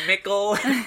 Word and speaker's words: Mickle: Thank you to Mickle, which rhymes with Ghost Pickle.